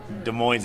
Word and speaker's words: Des Moines: Well, Des Moines doesn't even have all their letters pronounced, so whatever.